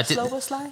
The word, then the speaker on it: Szoboszlai: Szoboszlai, is that? – That's what I'm going with, yeah. – Szoboszlai? – I think that's what the man himself said, so yeah, he should know.